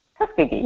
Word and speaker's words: Tuskegee: So it says, in 1932, the American government lied to African-American men living in Tuskegee, Alabama. Did I say that right? Tuskegee, yes.